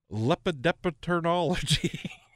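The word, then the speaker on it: lepidopterology: So lepidepterology.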